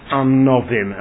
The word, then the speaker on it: Anovim: It's written Anniyim with a double Yud, but it's pronounced Anovim, and we'll speak about that.